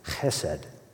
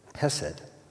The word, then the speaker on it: chesed: It's simply, or maybe not so simply, pronounced chesed. You can spell it just H-E-S-E-D, chesed. And there's no great English word that quite comes close.